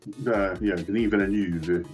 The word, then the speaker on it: Denis Villeneuve: correct? Denis Villeneuve? – Yeah, Denis Villeneuve.